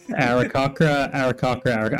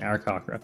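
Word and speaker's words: Aarakocra: Arakakra. I'm committing to that pronunciation, Arakakra.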